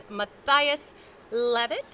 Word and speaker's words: Mathias Leavitt: I know that I think we're going to get more of the story on that, but this is Scott Davis and Mathias Leavitt.